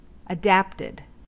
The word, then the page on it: adapted: American English